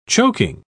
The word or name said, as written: choking